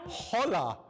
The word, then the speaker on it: hola: Hola. If you were in Spain, you would pronounce hello. Hola. How would you do it? – You'd say hola.